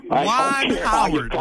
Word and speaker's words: Juwan Howard: But, you know, the best part of that rant, Ray, probably was the fact that you mispronounced my man Juwan Howard's name. Juwan Howard? Juwan Howard, Ray.